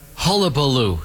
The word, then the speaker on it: hullabaloo: We were missing a syllable. – I had it and I lost. – You were saying hullaboo. We meant to say hullabaloo.